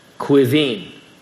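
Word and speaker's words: Caoimhín: A-O-I-B-H-E-A-N. A-O-I-B-H-E-A-N. It's pronounced Yvonne. How would you pronounce this boy name? C-A-O-I-M-H-I-N. C-A-O-I-M-H-I-N. Quivin.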